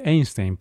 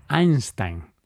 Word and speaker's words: Einstein: ¿Sabes que no se dice Einstein, Paco? ¿Cómo se dice? Cuéntame. Einstein.